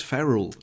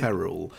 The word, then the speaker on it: ferrule: It is ferrule. It is ferrule. Which is F-E-R-R-U-L-E, in case my pronunciation wasn't getting that right.